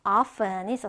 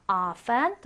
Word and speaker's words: often: 우리 자주라는 빈도부사 바로 often이 있었어요. often 또는 often 두 가지 발음이 다 가능했죠.